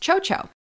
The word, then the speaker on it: XOXO: Anyway, in Spanish, apparently, at least in Spain Spanish, XOXO is pronounced chocho, which is a slang way to refer to the intimate female body part.